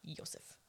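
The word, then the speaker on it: Iosif: And so Manus eventually successfully sells the box to a man named Josef Nitzsch. – Okay. – Which is such a cool name. – Josef Nitzsch. – And it's like, it's I-O-S-I-F. Josef. – Like E-O, like in Spanish or Italian.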